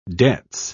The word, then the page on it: debts: British English